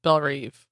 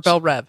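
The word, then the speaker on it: Belle Reve: Dr. Light is in Bell Reve, or Bell Rev. How do you say that? – I would say Bell Rev.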